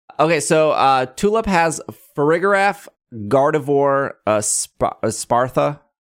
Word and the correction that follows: Espathra: How do we say this? – Espathra.